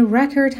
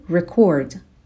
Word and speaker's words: record: Before Brad Bird arrived, Pixar already had a track record of encouraging talented people to push boundaries. به تلفظ کلمه record هم دقت کنین. استرس بخش اول کلمه هستش، چون اینجا یک اسمه. اگر فعل بود میشد record.